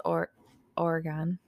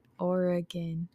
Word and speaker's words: Oregon: They stopped at a quiet spot on the road in Springfield, Oregon. – Oregon. – Oregon. – Oregon. Oh. Like, imagine Oregon, like you're playing one like a piano.